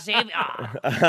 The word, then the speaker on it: Xavier: Well, I'm French, so it's it's an it's, X. It's heavy. Oh, oui, oui. Javier. Hong, hong. I'm so, I am Xavier.